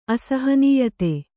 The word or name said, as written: ಅಸಹನೀಯತೆ